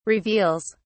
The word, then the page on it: reveals: British English